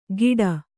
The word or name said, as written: ಗಿಡ